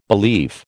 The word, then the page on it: belief: American English